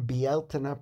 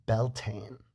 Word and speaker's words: Bealtaine: And if you do run into them, it's best to speak the language right, which means pronouncing Bealtaine properly in Irish, not saying Beltane.